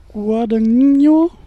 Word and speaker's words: Guadagnino: Wie wird denn der Regisseur ausgesprochen? Hat das jemand äh, erörtern können? Es ist Luca Guadagnino.